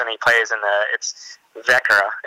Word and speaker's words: Växjö: So yeah, that's everyone butchers that Växjö or whatever they want to call it. So it's Vekra. – Vekra? – You know, Vekra. – Oh my gosh. – It's just based on the double O at the end and the silent A.